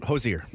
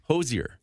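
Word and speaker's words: Hozier: Okay, so my girlfriend, she loves Hosier? Is that how you say it? – Hosier. – Yeah. – Hosier.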